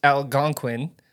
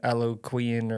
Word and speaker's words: Algonquin: Just so you know, it's pronounced Algonquin and not like I was saying Aloquian or whatever I said, yeah.